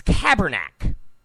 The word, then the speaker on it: Kaepernick: It's Kaepernick. My girlfriend was saying that. My girlfriend's a huge football fan, believe it or not. Huge football fan. So she says it's Kaepernick. Get the name right. It's Kaepernick.